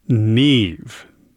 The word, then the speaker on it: Niamh: That's music by Ryan Murphy called Neve. Actually, spelled differently than you might expect, though. It's spelled N-I-A-M-H.